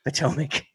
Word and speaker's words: Potomac: Potomac.